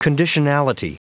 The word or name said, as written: conditionality